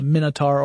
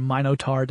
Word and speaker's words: Minotaur: out, and the Minotaur or Minotaur, depending upon your preferred pronunciation, is after him.